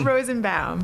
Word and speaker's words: Rozenbaum: Rozenbaum. – Rozenbaum. – Like Baum, chicka wow wow.